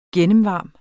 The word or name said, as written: gennemvarm